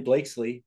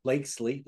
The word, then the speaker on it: Blakeslee: And today I'm extremely excited because I have Shay Ryan Blakesley with us. And I said that right? Blakesley.